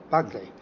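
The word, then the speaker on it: Berkeley: 呢、這個英國哲學家咧，佢愛爾蘭人嚟嘅，佢個發音係 Buckley。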